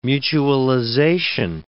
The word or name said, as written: mutualization